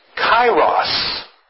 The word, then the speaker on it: kairos: The word that is used here is pronounced kairos.